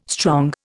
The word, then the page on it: strong: Indian